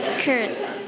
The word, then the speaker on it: skirt: Skirt. – What is that? – Skirt. – Skirt. Oh, exactly. Okay. This one we call skirt. – Skirt. – Skirt. Okay. บางคนอาจจะออกเสียงว่า skirt is incorrect. Okay, เนาะจำไว้ว่าถ้าตัว K อยู่ตามหลังตัว S เนี่ยจะ sound like กอไก่โอเคเนาะ skirt.